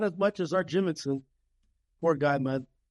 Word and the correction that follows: Jimmerson: You said Jimmonson. – Jimmerson, man. – I think I rest in peace, pioneer of the UFC, Art Jimmerson.